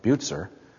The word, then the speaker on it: Bucer: After another brief stay in Basel, Calvin was persuaded by Bucer, I've been told that's the way to pronounce it, he was persuaded to come to Strasbourg.